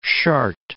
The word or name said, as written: Chartres